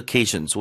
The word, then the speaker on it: occasion: 오로 시작한다 해서 오로 읽으면 안 되고요. K의 강세니까 앞에 음절은 으로 죽여서 occasion 이렇게 읽으셔야 돼요.